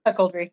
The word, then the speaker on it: cuckoldry: Cuckolded, is that how you pronounce it? – Cuckoldry, yep.